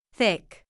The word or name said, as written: thick